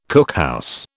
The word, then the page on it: cookhouse: British English